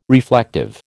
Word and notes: reflective: American English

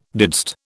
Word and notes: didst: British English

